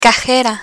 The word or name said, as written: cajera